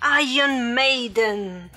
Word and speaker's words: Iron Maiden: Sim, Iron Maiden. Tá? Tô aqui com a minha pronúncia como os caras da própria banda pronunciariam, né?